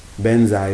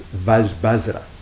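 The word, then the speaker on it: vajra: Benza is just how a lot of Tibetans pronounce it. But might I have to say, it's not every Tibetan pronounces it that way, okay? Some Tibetan pronounce it differently. The way my teacher would pronounce it is Bazra.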